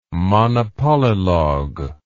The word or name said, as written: monopolylogue